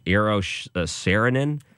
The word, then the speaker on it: Eero Saarinen: Eero Saarinen.